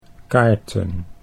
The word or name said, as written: kaarten